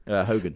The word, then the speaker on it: Hogan: Hogan, H-O-G-A-N.